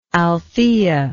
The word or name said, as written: althea